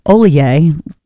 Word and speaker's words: Ollier: Looks like Hollier, but it's pronounced Ollier.